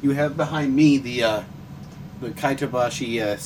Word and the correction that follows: Kotobukiya: statue of the first set. – Kotobukiya. – Yeah, whatever. Of the first set of armor. Can you give me that? I don't know how to pronounce the name. – Kotobukiya. – Kotobukiya. Okay.